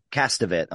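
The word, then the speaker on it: Castevet: We also have Sydney Blackmere as Roman Cassavet. That's the Castavet. I'm saying their name wrong.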